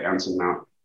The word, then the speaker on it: Anson Mount: I am saying that right, Anson Mount. – Yes, you are.